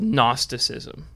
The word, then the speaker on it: Gnosticism: But he is going up against what is called Gnosticism, and it's spelled with a silent G.